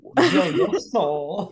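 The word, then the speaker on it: BelGioioso: There's a lot of O's in there. – Yeah, right. – Belgioso. – Gioioso. – You're right. There is Gioioso.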